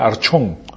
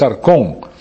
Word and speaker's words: arcón: Ese es arcón, se letrea A-R-C-H. Si lo puedes pronunciar en español, sería archón, pero es arcón.